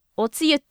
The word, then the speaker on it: お汁: この作品に限らず、あれおつゆって読むこと初めて知りました。今までずっとこれお汁って読むかと思っていて、いやだってお汁粉とかあれ読み方汁じゃないですか。